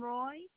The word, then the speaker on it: Roy: Catherine Roy. – Catherine Roy. Can you spell that last name? – R-O-Y, yes.